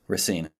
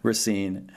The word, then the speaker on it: Racine: And the other is Nate Leanhard, who lives in, I'm not going to say this right, mm-hmm. Racine. Did I get close? Racine. I've already been corrected on that. Racine.